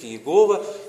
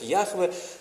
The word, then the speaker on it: Яхве: Это имя звучит как Иегова, или вернее мы потеряли его настоящее истинное звучание, многие ученые сегодня произносят его как Яхве,